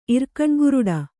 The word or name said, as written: ಇರ್ಕಣ್ಗುರುಡ